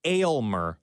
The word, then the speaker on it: Aylmer: But Weaver had a caller yesterday emphatically say it's pronounced Aylmer, like ale, the beer.